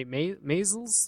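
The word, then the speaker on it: Maysles: Maisel's? That's how you pronounce it.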